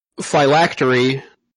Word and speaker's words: phylactery: Says here, phylactery. I said that right. Right? That's yep.